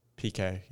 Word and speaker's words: Piquet: Piquet, yeah.